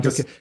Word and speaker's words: DeSantis: So, Florida, Ron DeSantis, am I saying that right? Ron DeSantis? – I believe that's correct. – Yeah. – Okay, that's correct. That's Ron DeSantis.